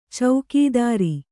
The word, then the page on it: ಚೌಕೀದಾರ: caukīdāra